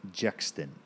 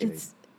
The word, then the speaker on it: JXDN: Also can be pronounced Jexton. – Not really. It's spelled J-X-D-N. – But it's pronounced Jaden.